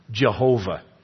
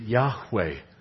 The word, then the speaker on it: Yahweh: That's an improper pronunciation, by the way, because in the Hebrew, there is no J and there is no V. The Hebrews could not have called their God Jehovah. They would have pronounced it Yahweh.